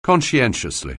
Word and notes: conscientiously: anglais

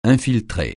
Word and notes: infiltrer: francais

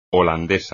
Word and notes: holandés: espagnol

